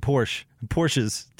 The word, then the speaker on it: Porsche: Porsches to own. – I only have one Porsche. It's pronounced Porsche.